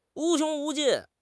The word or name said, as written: 无穷无尽